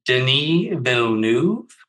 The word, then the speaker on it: Denis Villeneuve: So for example, for now, let's just assume you're talking about, say how to pronounce his name again. I always get it wrong. I need to hear it. – I'm probably not pronouncing it as well. Denis Villeneuve.